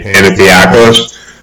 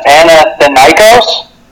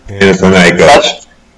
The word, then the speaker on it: Panathinaikos: Panathiakos. Do we have the pronunciation right? – Panathinaikos? – Panathinaikos.